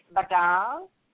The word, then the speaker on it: badal: And the Hindi word is badal, and it's spelled B-A-D-A-L-A. That's B as in boy, A, D as in dog, A-L-A. Badal.